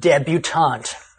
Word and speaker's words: debutante: However, there is a related word that is pronounced with the T sound, debutante.